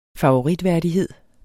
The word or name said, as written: favoritværdighed